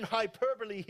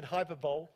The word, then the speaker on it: hyperbole: And I had to look that up to get it right, because otherwise I would have said hyperbole. Hyperbole. I had to go, Siri, how do you say that? Hyperbole.